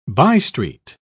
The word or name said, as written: bystreet